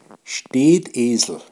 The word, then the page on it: Stedesl: Pinzgauer Mundart